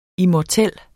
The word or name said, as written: immortel